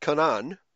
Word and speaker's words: Canaan: But interestingly, I, am noticing that your pronunciation of the, you're pronouncing it Canaan, which is probably the, the true pronunciation of the Hebrew word.